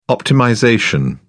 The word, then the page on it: optimization: British English